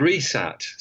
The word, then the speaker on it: WRESAT: And so it was called RESAT, spelt with a silent W at the beginning, WRESAT, which is the Weapons Research Establishment satellite.